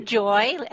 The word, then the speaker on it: Joy: It's actually Joy, as in joy to the world kind of thing.